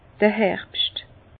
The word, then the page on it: d'r Herbscht: Bas Rhin; Herrlisheim